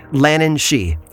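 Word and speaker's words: Lhiannan Shee: It's spelled differently but pronounced the same, Lannan Shi, although this creature is far more dangerous than her Irish counterpart.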